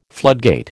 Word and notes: floodgate: American English

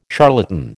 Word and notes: charlatan: American English

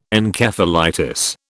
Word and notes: Encephalitis: British English

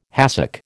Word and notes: hassock: American English

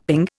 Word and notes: ping: Indian

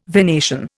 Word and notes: venation: Indian

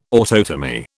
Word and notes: autotomy: British English

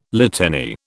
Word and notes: litany: British English

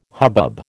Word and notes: hubbub: American English

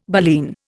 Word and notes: baleen: Indian

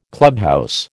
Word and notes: clubhouse: American English

